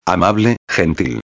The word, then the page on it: amable gentil: espanhol